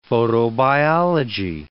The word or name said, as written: photobiology